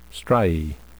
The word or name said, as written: striae